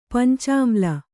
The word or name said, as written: ಪಂಚಾಮ್ಲ